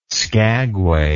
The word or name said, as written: skagway